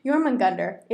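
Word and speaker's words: Jörmungandr: But Jormungandr, it's J and O with those two little dots over it. – Oh, umlau. – Yeah. J, O with two dots.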